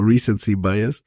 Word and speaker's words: Recency Bias: Genau, Recency Bias.